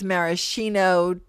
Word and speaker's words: maraschino: Maraschino versus maraschino, tomato, tomato, filo, filo. – That's not pronounced shito. It's Schino. – Maraschino, not the maraschino. It's the maraschino.